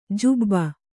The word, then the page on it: ಜೂಬ: jūba